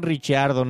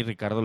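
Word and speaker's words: Ricciardo: Y lo pronuncia Sebastián Richardo, no, no Ricardo, como él mismo, como Dani Ricardo lo dice.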